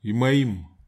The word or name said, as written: imaim